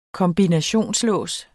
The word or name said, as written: kombinationslås